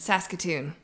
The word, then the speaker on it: Saskatoon: Saskatoon.